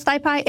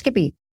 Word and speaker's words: SciPy: Some folks may call it SciPy. It is pronounced Skippy.